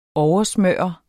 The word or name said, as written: oversmøre